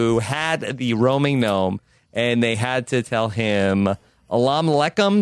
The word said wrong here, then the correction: Assalamu alaikum: Did I say that right, Jess? – Assalamu alaikum. – Assalamu alaikum.